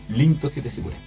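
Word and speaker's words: Linto Siete Sigure (凛として時雨): Rinto Siete Sigure. Se pronuncia en español Linto Siete Sigure.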